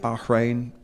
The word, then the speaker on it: Bahrain: So the controversy is that about whether or not Bahrain is an ethical sponsor to be involved in professional cycling. – Nice pronunciation of Bahrain there.